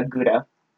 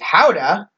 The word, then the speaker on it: Gouda: Oh, I've officially become that person correcting people who say Gouda. – Tell them how you pronounce. – So, how the Dutch pronounce it is Houda.